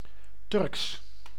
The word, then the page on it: Turkish: American English; British English; /ˈtə.kɪʃ/; /ˈtɝ.kɪʃ/